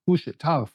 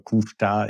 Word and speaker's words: Couche-Tard: Ich glaube, deine Aussprache war perfekt. Es ist ein kanadischer Wert und ich glaube, er kommt auch aus dem französischen Teil äh, Kanadas. Also äh, Kuhstar ist schon richtig ausgesprochen.